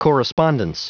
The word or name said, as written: correspondence